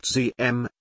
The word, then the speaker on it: Diem: Ington assumed that Diem, pronounced ZM, was an effective leader and that South Vietnam was on its way to successful nation building.